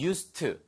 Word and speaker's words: used to: 발음 하나만 주의하세요. We used가 아니고요. We used to. 그래서 뒤에 the 발음이 나지 않습니다.